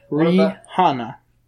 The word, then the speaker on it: Rihanna: I just don't know, listening, Beyonce, Rihanna, I think is actually how it's pronounced.